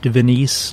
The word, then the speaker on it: Venice: Today's topic is Villa Venice, spelled like Venice, Italy, pronounced Venice like Denise,